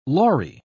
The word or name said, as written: lorry